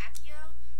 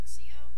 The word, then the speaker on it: Accio: Well, they also said Accio, except in the video game they say Axio.